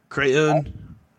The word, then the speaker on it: crayon: Justin said Justin said C-R-A-N. – Crayon. Crayon? – Yeah, hold on. I'm going to look up something now. – It's two syllables. It's not one syllable. Cran is one syllable. Crayon, two syllables.